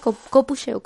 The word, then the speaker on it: copucheo: Eso sí, un capucheo, capucheo. No, ¿cómo se dice? Copucheo, copucheo, copucheo.